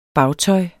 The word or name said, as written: bagtøj